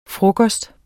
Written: frokost

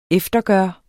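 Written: EFTERGØRES

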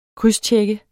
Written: krydstjek

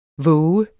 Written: vågen

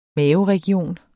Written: maveregion

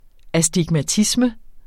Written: astigmatisme

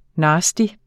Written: nasty

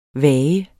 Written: vage

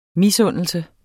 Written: misundelse

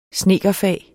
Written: snedkerfag

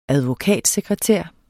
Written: advokatsekretær

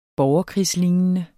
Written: borgerkrigslignende

